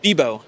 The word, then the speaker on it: Bibeau: Bebo.